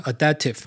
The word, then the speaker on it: AIDAPTIV: 好，那我们上一集 QA 有一个朋友在问我说，群联哦 Phison 他有一个 AI Adaptive 的方案，他们公司把它念成 Adaptive 哦，但拼音是 AIDAPTIV。